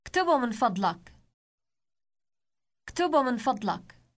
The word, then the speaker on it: اكتبه: اكتبه.